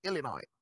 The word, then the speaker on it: Illinois: Don't pronounce the S, please. Illinois.